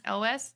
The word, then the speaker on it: Elwes: Elwes.